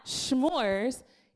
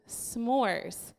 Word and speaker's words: s'mores: She took a poll to prove to me, and we called people to prove to me that it's not schmores, it's s'mores.